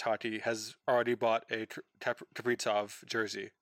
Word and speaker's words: Kaprizov: It's actually pronounced Kaprizov.